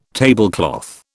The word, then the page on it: tablecloth: British English